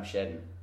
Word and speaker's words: Shedden: Yeah, it's Sam Shedden. And Shedden is spelled S-H-E-D-D-E-N.